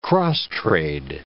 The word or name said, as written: cross-trade